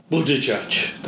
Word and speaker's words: Buttigieg: What's his name? Buttigieg. That's how you pronounce his name.